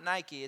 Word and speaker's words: Nike: It's also tied to another Greek word called Nike, which when you look at it, it's spelled N-I-K-E. So it's not Nike, it's actually Nike is how you say it.